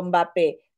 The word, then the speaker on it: Mbappé: Mbappé.